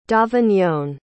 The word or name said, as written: davignon